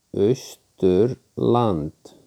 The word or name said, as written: Austurland